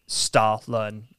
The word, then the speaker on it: Stollen: It's spelled STOLLEN, but if you were to pronounce that in the pronunciation sure. – by Germans, it's Stahlun.